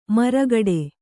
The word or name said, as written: ಮರಗೋಡು